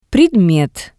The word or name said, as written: Predmet